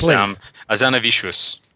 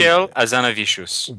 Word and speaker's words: Hazanavicius: This is directed by Michael Hazan. And how do you pronounce his name? – I'll do it. It's Hazanavicius. – Thank you. – Michel Hazanavicius.